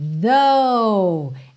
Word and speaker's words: though: Essa palavra aí vou até soletrar para quem está ouvindo no podcast T-H-O-U-G-H. A pronúncia é though, com th, th, th, ok? Som aí nesse TH, a linguinha lá nos dentes da frente, though, tá?